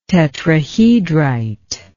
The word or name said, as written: tetrahedrite